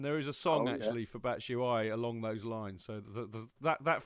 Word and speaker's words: Batshuayi: Is that the official pronunciation? – Yes, Batshuayi? I believe so. Batshuayi. – Batshuayi. – Batshuayi. So I'm told.